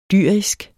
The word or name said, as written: dyrisk